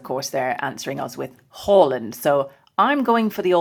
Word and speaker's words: Haaland: Yeah, Lindsay and I are in this camp, aren't we, that the correct way to say his name is Erling Haaland.